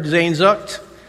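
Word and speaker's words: Sehnsucht: So in case you're wondering how to say that word, Zainzucht is my phonetic spelling of it.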